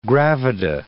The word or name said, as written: gravida